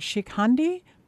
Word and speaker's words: Shikhandi: That's titled, I'm going to try to say it, Shikhandi? – You're right. – Did I say it right? – Yes, you did.